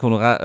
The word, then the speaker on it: Honorat: Ähm, äh, Frank Honorat oder Honorar, wie weiß, weiß nicht, wie er ausgesprochen wird, mit stummem T. – Ja, genau, Frank Honorat äh, scheitert dann an Nübel, ähm, war dabei wohl knapp im Abseits.